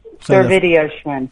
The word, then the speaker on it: Servidio-Schwinn: Servidio-Schwinn.